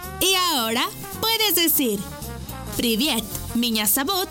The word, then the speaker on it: меня зовут: Y se escribe P-R-I-V-I-E-T. Привет. ¡Muy bien! Repite conmigo. Привет. ¡Muy bien! En ruso, me llamo se dice меня зовут. ¿Y tu nombre? Una vez más. Меня зовут. Por ejemplo, меня зовут. Esta palabra se escribe меня. M-I-Ñ-A. Меня. Зовут. Z-A-V-U-T.